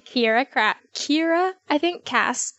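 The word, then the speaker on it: Kiera Cass: The first book was called The Selection by Kira Cass. That's how you say her name.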